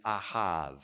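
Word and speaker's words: ahav (אהב): In Hebrew, starting with that one, Leviticus 19:18, when it says love your neighbor, the Hebrew word is ahav. That's how you say it in Hebrew.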